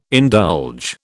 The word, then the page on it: indulge: British English